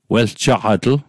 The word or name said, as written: weljaqatl